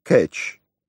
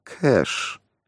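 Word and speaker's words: cache: Он произнес «кэш» как «кэтч», но почти мгновенно сообразил, что он имел в виду. Английское слово со значением «тайник», заимствованное из французского языка, произносится как «кэш», тогда как «кэтч» — это ловушка, загвоздка.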